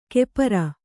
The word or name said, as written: ಕೆಪರ